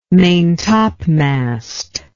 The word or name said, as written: maintopmast